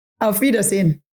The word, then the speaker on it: Auf Wiedersehen: Auf Wiedersehen. – Auf Wiedersehen.